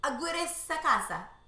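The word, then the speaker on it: Aguirre-Sacasa: Aguirre Sacasa.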